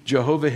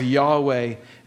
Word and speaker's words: Yahweh: Jesus claimed to be Jehovah himself, Yahweh, as it's pronounced more properly, and he makes these seven I am statements.